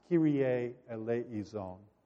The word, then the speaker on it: Kyrie eleison: It's pronounced Kyrie eleison, Lord, have mercy.